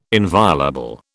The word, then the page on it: inviolable: British English